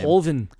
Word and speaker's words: Verhoeven: Okay, so after seeing Sharon Stone's performance as Laurie in this movie, Paul Verhoeven? I can't remember how to say his name. – Verhoeven. – He's the one.